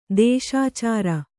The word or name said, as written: ದೋಷಾಚರ